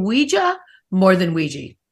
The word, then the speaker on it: Ouija: I prefer Ouija.